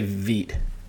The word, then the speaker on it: Veidt: Is it Veidt or Veidt? I don't even know how to pronounce it. – I would say Veidt.